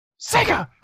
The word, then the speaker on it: Sega: You didn't say it right. – Sega! Master System.